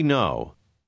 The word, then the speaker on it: know: Lisez le numéro 7 en vous rappelant que le cas est muet. – We know.